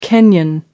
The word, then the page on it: Kenyan: American English